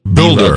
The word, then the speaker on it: Bulder: Roland Boulder. Is that pronounced right? Boulder? – Builder. B-U-L-D-E-R. Builder. – Builder? B-U-L-D-E-R is Builder? – Builder. Bull. Bull. Yeah.